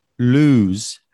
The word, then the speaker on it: lose: Lose has a long vowel sound, lose, and it's only got one vowel, well, one O. And loose has shorter vowel sound, and it's got two O's.